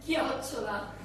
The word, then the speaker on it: Chiocciola: Chiocciola. – Chiocciola. Have I said that right? Chiocciola.